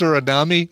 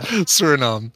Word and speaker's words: Suriname: Fox figure says it's pronounced Suriname. It is not pronounced Suriname. It's pronounced Suriname.